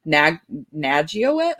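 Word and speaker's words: Nageotte: And then, Katie Nagyowicz, I'm sorry, I don't know how to say her last name. – Oh, you nailed it.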